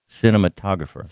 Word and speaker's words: cinematographer: Cinematographer. – That's what it is, but you told me to say cinema photographer. – Right, I put an extra something in it. – Oh, you had an extra syllable.